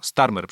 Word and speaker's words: Starmer: Lider brytyjskiej partii pracy Keir Stramer, y, chyba tak to się wymawia, Starmer, przepraszam,